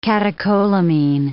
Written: catecholamine

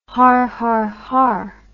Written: harharhar

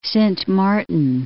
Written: Sint Maarten

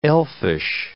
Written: elfish

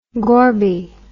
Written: Gorby

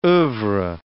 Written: oeuvres

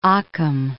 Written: Occam

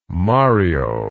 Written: Mario

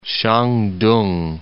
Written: Shan-tung

